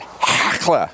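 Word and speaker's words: Hachaliah: You got to say it like you got something in your throat. Come on, Hakla.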